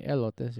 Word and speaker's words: elotes: Sleeping on some elote. Is it called elote or am I saying it wrong? – Elotes, yeah.